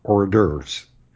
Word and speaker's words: hors d'oeuvres: So Americans have come pretty close by standardizing on hors d'oeuvres. And that's what most people say. And I think that is, in fact, the standard English pronunciation.